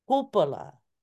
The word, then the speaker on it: Kalupa: And then Kalupa, it's not a common name. And I was told it's actually pronounced Hupala, Hupala. The K sound doesn't exist in that tatat.